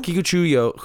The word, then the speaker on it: Kikuchiyo: Kikuchiyo? – Yes. – Kikuchiyo? – Kikuchiyo, who's played by the inimitable Toshiro Mifune.